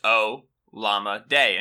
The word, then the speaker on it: Olamide: Yeah, so so so okay, so his name is is Olamide. O L A M I D E, mm-hmm. – right? – But it's pronounced O Llama Day. And his at on Twitter is Llama Day, like the word llama and the word day, which I think is tremendous.